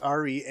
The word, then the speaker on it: SFR3: SFR3, pronounced Ari, and he just, like, studies creatures.